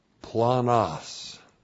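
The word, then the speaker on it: planos (πλάνος): And then we have the adjective planos. Looks like that in the Greek, that's P-L-A-N-O-S, planos.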